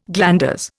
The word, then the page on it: glanders: Indian